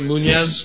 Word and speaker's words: Muniz: Frankie Muniz, the kid from Malcolm in the Middle. – Am I pronouncing that right? Muniz? – Yes.